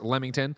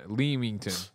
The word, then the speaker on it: Leamington: It is pronounced Leamington, Leamington. Yeah, okay, great.